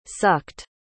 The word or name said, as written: sucked